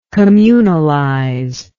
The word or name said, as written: communalization